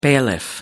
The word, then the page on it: bailiff: American English